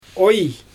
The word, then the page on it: ooij: Xyzyl